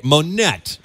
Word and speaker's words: Monett: M-O-N-E-T-T, Monet or Monette? – No Monette? – Idea. – Monette? Okay, Monette.